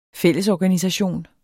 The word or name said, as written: fællesorganisation